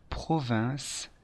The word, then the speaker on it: province: Attention, il faut bien prononcer province et ne pas confondre avec la Provence.